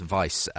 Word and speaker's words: Wiebe: One really interesting chapter is by Philip, I think it's pronounced Weiebe, W-I-E-B-E.